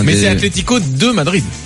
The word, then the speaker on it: Atlético: L'accent sur le. C'est Atlético. – Ah oui, non, les accents, j'ai vite abandonné, moi. – Ouais, c'est compliqué, hein, mais voilà. C'est ce qu'on appelle l'accent tonique et c'est sur le. Là, c'est accentué sur la deuxième saison.